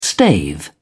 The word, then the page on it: stave: British English